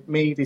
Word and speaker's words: Mead: It's a throw on words, Mandalorian Mead. And so Mead is spelled M-E-A-D.